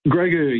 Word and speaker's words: Grogu: A Mandalorian and, how do you pronounce it, Grogu? – Grogu, yeah, Grogu.